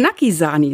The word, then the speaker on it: Naki Sani: Habe ich dich richtig ausgesprochen? – Nein. – Na, sag mal, wie heißt du richtig? – Naki Sani. – Naki Sani.